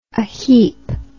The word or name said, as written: aheap